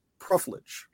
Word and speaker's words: profligate: But the main reason was because her father, I want to try to use this word right again, was very profligate. Correct? – Yeah. Is that how you... Okay. Profligate, definitely. We pronounce it in Britain.